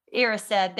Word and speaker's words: Erised: It was like a really messed up version of the Mirror of Erised or Erised from Harry Potter. I know I say that wrong, but... – Erised, there we go.